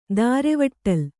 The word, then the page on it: ದಾರೆವಟ್ಟಲ್: Kannada